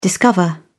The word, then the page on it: discover: British English